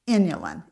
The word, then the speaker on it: inulin: Inulin. It's spelled I-N-U-L-I-N, inulin.